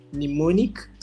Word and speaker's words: Mnemonic: Andre is the CEO at Mnemonic.